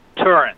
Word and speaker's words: Turin: Turin. – Turin. Okay, so Turin. The accent's on the first syllable, Turin.